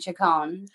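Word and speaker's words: Chacón: And then I met my now husband, which is maybe why you're like, what is your last name? How do you say it? It's Costa Rican, Chacon.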